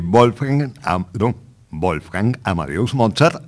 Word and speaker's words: Wolfgang Amadeus Mozart: Vamos a escuchar de Wolfgang Amadeus Mozart, así se pronuncia,